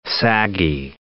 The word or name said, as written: saggy